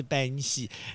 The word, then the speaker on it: 扁食: 扁食，对，其实它是来自于闽南语的发音，就是扁食。